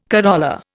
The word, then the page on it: corolla: Indian